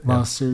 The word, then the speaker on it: Lao Tzu: How do you say Lao Tzu? – Lao Tzu, yeah.